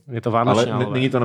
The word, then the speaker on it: Vánočniálové: Vánoční, vánoční, alové je tady i i, eh, je tady i napsáno, jak se to má vyslovovat, a to je Vánočňálové.